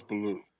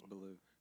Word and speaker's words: balut: No, it's balut. – Not the bear from Jungle Book. – That's balut. – Yeah, I know. Sounds really like it. Balut